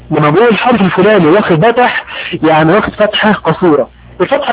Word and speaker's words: فتح: الفتح القصير اسمه بتح، بتح بتح بتح. يعني ايه بتح؟ يعني فتح واضح من الكلمة، مكونة من ثلاث حروف فاء وتاء وحاء، لكن اول حرف اللي هو فاء ينطق باء في هذه الكلمة.